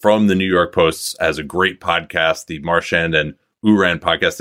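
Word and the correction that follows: Ourand: Did I pronounce John's name correctly, by the way? – He did not. It's Oran.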